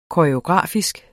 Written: koreografisk

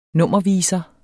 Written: nummerviser

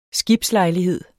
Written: skibslejlighed

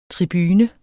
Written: TRIBUNERNES

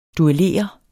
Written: Dueller

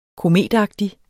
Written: kometagtig

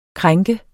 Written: krænke

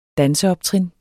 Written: danseoptrin

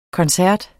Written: koncert

